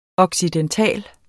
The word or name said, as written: occidental